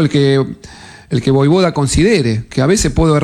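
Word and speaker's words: Vojvoda: Y además confirmando que su apellido es Voivoda. – Claro. Se pronuncia de esa manera.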